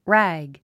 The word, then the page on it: rag: rǽg; ラァグ